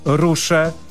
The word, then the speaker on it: Ruxe: X po galicyjsku wymawia się jak szy, czyli powinno być rusze, rusze.